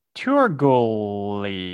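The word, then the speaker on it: Teurgoule: This one is Turgole. It's T-E-U-R-G-O-U-L-E.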